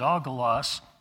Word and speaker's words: angelos: And that word in English, we would pronounce it angelos.